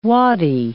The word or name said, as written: waddie